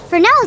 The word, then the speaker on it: Fresnel: Fresnel is a funny word, isn't it? The spelling bee would love it. It has a silent S, and it's spelled F-R-E-S-N-E-L.